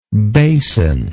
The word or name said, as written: basinlike